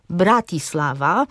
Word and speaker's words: Bratislava: I zasadniczo polski akcent pada na drugą sylabę od końca wyrazu. Inaczej mówiąc, na przedostatnią. Odróżnia to język polski na przykład od języków czeskiego czy słowackiego, w którym akcent pada na pierwszą sylabę wyrazu. Tam usłyszymy nazwy Bratislava, czy Banska Bystrice, czy Karlowe Wary.